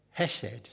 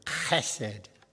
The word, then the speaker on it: chesed: He said hesed, spelt with a H, which actually is a blasphemous word. He should have said chesed, like the loch, when you say a Scottish loch, chesed.